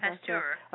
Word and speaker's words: Pasteur: You know, who knows if the person who came up, Louis Pasteur, right? Did I say that right? – Pasteur? You did. Pasteur.